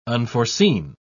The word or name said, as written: unforeseen